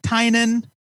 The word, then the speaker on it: Tynion: I believe he has since corrected the internet by saying it's pronounced Tynion.